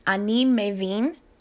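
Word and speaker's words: Ani mevin (אני מבין): This is ani mevin if you are a man, or ani mevina if you're a female. Ani mevin, ani mevina. Pay attention to the stress on the last syllable.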